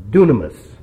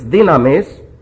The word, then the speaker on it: dynamis: One is the Greek word dynamis. Sometimes pronounced dunamis. It's dynamis, and it's transliterated dynamic. D-Y-N-A-M-I-C.